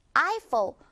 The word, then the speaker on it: Eiffel: 우리말로는 에펠탑이라고 하지만 영어로는 The Eiffel Tower. Eiffel. 이렇게 발음해요.